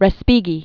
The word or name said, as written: Respighi